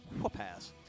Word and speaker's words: Whoop-Ass: I pronounced the H. Whoop-Ass.